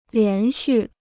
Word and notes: 连续: lián xù

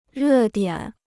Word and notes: 热点: rè diǎn

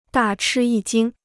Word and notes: 大吃一惊: dà chī yī jīng

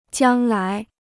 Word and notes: 将来: jiāng lái

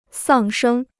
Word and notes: 丧生: sàng shēng

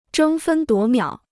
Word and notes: 争分夺秒: zhēng fēn duó miǎo

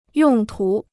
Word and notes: 用途: yòng tú

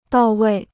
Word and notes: 到位: dào wèi

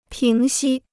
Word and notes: 平息: píng xī

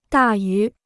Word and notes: 大于: dà yú

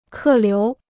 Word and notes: 客流: kè liú

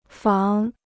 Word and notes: 仿: fǎng

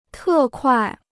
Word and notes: 特快: tè kuài

